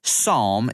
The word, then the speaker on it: psalm: Now, the word psalm is spelled P-S-A-L-M, but it is not pronounced psalm, Trevor. It's just pronounced psalm. The P's silent.